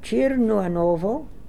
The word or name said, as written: Chirnooanovo